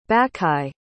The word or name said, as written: bacchae